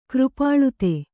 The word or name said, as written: ಕೃಪಾಳುತೆ